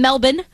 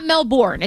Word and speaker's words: Melbourne: Melbourne, that's, yeah. – Or and Melbourne. Melbourne. Apparently they don't pronounce the R. – Okay, so no R's. – No, Melbourne. Okay.